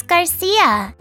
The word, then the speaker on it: Garcia: It's Garcia. – How do you spell that? – G-A-R-C-I-A.